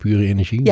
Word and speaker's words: Pure Energie: Oh, zo spreek je het ook uit, pure energie. – Ja. – Oh, ik ben veel te trendy. – Ja, nee, maar er zijn ook logo's rondgegaan met het Engels.